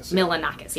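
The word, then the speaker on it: Milonakis: Am I saying it right? – Milanakis.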